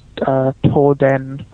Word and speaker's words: Toden: So essentially, at the power station itself, you have employees of Tokyo Electric Power Company, TEPCO, as foreigners call it, but in Japanese it's Toden.